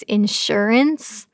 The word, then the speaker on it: insurance: I've been saying insurance all my life, I guess, and it's insurance. I was putting the wrong emphasis on the wrong syllable.